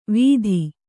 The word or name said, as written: ವೀಥಿ